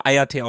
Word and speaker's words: Hayate: Hayate, das H ist stumm. – Ja, mein, okay, Mann. Hayate. – Ich hab's mir extra aufgeschrieben, das H ist stumm. – Okay, okay. – Hayate geschrieben, aber Hayate ausgesprochen.